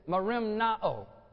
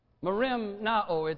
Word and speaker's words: merimnao: It's the Greek word is merimnao. Merimnao. It's phonetically spelled M-E-R-I-M-N-A-O.